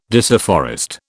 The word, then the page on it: disafforest: British English